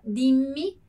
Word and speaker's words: dimmi: Então, pronuncia dimmi. Dimmi, né? Importante aqui, le doppie consonanti.